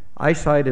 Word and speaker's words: iCyt: And let me tell you about EyeSight. That's spelled I-C-Y-T. EyeSight is a medical technology company that was created in the research park. The name is self-explanatory. Eye, obviously that's Illinois. Sight, well that's for flow cytometry, the business of sorting human cells, a technology that's incredibly important to medicine.